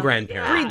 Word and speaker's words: grand-père: I'm a young grand-père. – Did I even say that right? Grand-père? – It's perfect.